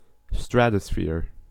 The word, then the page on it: stratosphere: American English